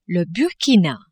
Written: Burkina

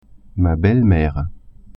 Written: belle-mere

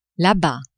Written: la-bas

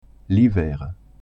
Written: hiver

